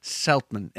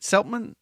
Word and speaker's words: Seltman: And with me, once again, is my two amazing guests and hysterically funny comedians, Jesse Bickford and Kristen Seltman. And am I pronouncing it right? Is it Seltman? Seltman? – Yes. – I think I'm putting too much emphasis on the LT.